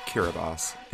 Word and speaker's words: Kiribati: Although the country is pronounced Kiribati, it is spelled Kiribati.